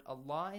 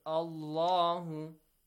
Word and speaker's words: Allah: If the previous sound or the previous harakat on the previous letter is a fatha or a dhamma, the word, the lam of the word Allah is pronounced heavily.